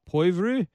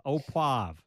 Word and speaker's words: poivre: I want to check out at least one of them, and you're going to have to help me with the pronouncer. My French isn't so good. Strip steak au poivre for pepper. – Poivre. – Au poivre. Now I know.